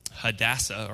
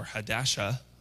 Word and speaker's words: Hadassah: Anyhow, her real name is like Hadassah, or Hadasha, however you want to pronounce it. Hadassah. See, I couldn't have said that.